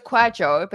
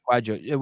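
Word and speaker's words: Kwadwo: A Dr. Quadwo Kyrimantang. Kyrimantang. – So it's Quadjo, but you did well with the second name. – Quadjo, with a J kind of, Quadjo? – Yeah, like that.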